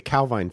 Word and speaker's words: Calvine: So, it's a thing called the and I hope I'm saying this right, the Calvine photo. And it was How do you spell that? – It's C-A-L-V-I-N-E. Calvine. – Okay, Calvine. – There you go.